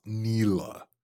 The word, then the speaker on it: Nilah: And yes, it's pronounced Nilah. They even say it in game.